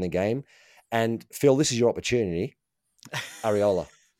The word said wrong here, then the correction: Iraola: And sorry, I got the pronunciation wrong. Yeah, I knew you were going to correct me. You do that really well, actually, without saying to me, no, you got that wrong. You give me the proper pronunciation. I love it. – Good, yeah. Shall we practice it together? Iraola.